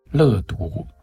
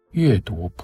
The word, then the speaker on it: 乐读: 我是杨照，在今天的节目当中，要为大家介绍的这是焦元溥的新书，由联经出版公司刚刚出版，书名叫做《乐读普希金》，或者是也可以读成《阅读普希金》。